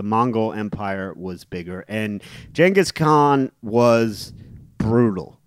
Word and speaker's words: Genghis Khan: and I've always been fascinated by Genghis Khan, or I believe it's actually technically pronounced Genghis Khan, but no one says that.